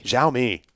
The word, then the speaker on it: Xiaomi: Xiaomi.